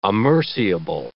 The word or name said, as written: amerciable